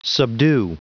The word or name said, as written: subdue